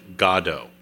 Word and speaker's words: Godot: Well, just so you know, the proper pronunciation is Godot. – Godot? So it sounds like God.